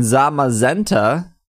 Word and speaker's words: Zamazenta: We have actual pronunciations on this. Zacian and Zamazenta